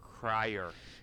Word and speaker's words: Cryer: Jon Cryer am I saying that right? Is it Cryer? – Yeah. – Or is it Kreischer? Kreischer. – Cryer. – Cryer.